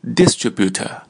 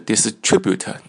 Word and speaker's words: distributor: 第三个单词注意发音，不是 distributor 也不是 distributor, 是 distributor, 它这个重音在 TRI 上面， distrib 就是在第二个音节上面。